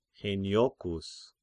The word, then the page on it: Heniochians: Heniochous